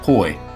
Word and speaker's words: Hui: Or do we say it like we would say Arkansas Suey, Hui? How are we pronouncing that this year? – Hoy. – Hoy? Like a Georgetown Hoya? – Like the Hoy Polloi.